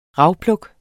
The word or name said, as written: rawlplug